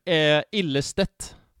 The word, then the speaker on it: Ilstedt: Ilstedt. – Ilstedt.